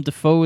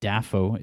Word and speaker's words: Dafoe: His real name's William, and he says half of his family pronounce his surname with the stress on the first syllable. So before starting acting, he was William Dafoe.